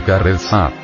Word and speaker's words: carezza: La palabra cabeza, pronunciada carezza, viene del italiano y quiere decir una caricia.